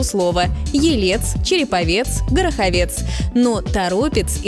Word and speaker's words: Торопец: Хотя в новостях можно услышать и Торопец, но это ошибка. Объяснение здесь вполне прозрачное. Название города связано с рекой Торопа, от которой оно и образовано. Поэтому ударение сохраняется в корне слова – Торопец.